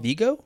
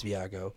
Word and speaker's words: Viago: Vigo? – Vigo. – Vigo? – No, it's not Vigo because there's an A in it. – Viago. I think you're right. It's Viago. Is that...